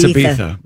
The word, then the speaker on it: Ibiza: It's Ibiza, by the way. – Come on with that. – The Z is pronounced TH. I'm sorry.